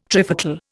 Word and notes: trifocal: Indian

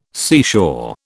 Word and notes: seashore: British English

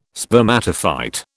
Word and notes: spermatophyte: British English

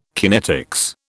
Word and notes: kinetics: British English

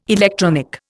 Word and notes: electronic: Indian

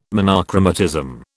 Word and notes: monochromatism: British English

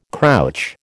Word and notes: crouch: American English

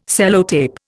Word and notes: Sellotape: Indian

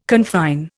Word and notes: confine: Indian

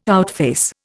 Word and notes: outface: Indian

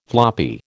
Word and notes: floppy: American English

